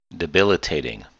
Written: debilitating

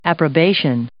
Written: approbation

